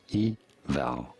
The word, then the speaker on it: Yveltal: Yveltal. – Yveltal. – You do want to stress on the second syllable. On the vowel syllable, Yveltal.